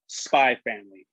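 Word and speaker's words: Spy × Family: So, the manga I have for this week is called Spy Family or Spy X Family, but the X is not pronounced because, you know people do what Hunter.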